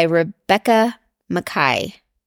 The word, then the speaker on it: Rebecca Makkai: The first book I'm going to talk about today is I Have Some Questions for You by Rebecca Makkai.